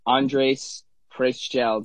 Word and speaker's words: Andres Preschel: Andres PRESCHEL.